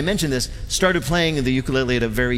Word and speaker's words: ukulele: the ukulele.